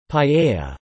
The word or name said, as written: paella